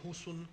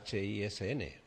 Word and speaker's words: Hisn: Si Hisan es difícil de pronunciar, porque nosotros, bueno, como lengua latina que utilizamos, necesitamos las vocales, podemos decir Husun, que es lo mismo. Y a lo mejor no sale más. – Bueno, es Hisn.